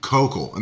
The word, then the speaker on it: Koukl: Greg Koukl, and that's K O U K L, I believe is how you pronounce his last name, Gregory Koukl.